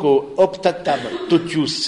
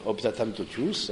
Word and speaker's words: Optatam totius: Et donc, Optatam totius, c'est comme ça ça se prononce, le latin c'est très facile de ce point de vue-là, Optatam totius, hein, et donc du coup, et c'est au numéro 11.